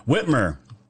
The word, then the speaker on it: Whitmer: Whitmer.